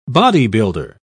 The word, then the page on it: bodybuilder: British English